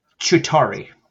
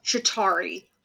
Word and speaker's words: Chitauri: How do you pronounce this word? I always fuck this up. – Chitauri. – Chitauri.